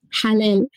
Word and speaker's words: halal: How can healthcare providers take food-related practices into account, such as halal? Did I say that right? – Halal. – Halal, I'm so sorry.